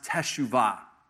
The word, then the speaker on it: teshuvah: It's a Hebrew word, and it's pronounced teshuvah.